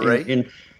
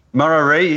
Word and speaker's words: Murarrie: Murarrie, is that how you pronounce it? – Murarrie. – Murarrie, yeah, God, I knew I'd get it wrong, in, you know, Brisbane's eastern suburbs.